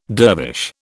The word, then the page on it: dervish: British English